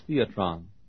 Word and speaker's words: theatron: Where it says the word spectacle, the word in the Greek is pronounced theatron. We get our word theater from it.